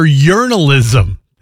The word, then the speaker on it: Yearnalism: New music from Baby Rose and Better here on 88.9 with my favorite album title so far of the year, Yearnalism. That's Y-E-A-R-N-A-L-I-S-M. Pronounced like journalism, but you know, the yearning, the loving, the feeling that you get.